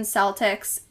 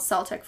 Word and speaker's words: Celtic: The 1926 edition says Celtic is preferred, but the 1996 edition says Celtic is preferred, with an exception to the Boston Celtics and a soccer team called Celtic Football Club. So we can pronounce it Celtic or Celtic, and it's not wrong. – They're both correct.